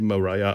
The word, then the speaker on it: Maria: Schreibt sich zwar nicht wie Mariah Carey, sondern wie Maria, wird aber wie Mariah ausgesprochen, also ohne H hinten dran.